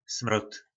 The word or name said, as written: Smrod